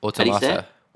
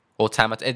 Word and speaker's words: automata: Automata. Automata. It depends on like where you're from, different people, but it's I'm not going to spell it. It's auto and then meta.